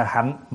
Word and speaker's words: hạnh: Chữ hành hoặc là hạnh, nó cùng một cái chữ thôi. Ăn thua mình phát âm. Thí dụ như đại hạnh phổ hiền, nhưng mà chúng ta có thể đọc là đại hành phổ hiền cũng được nữa.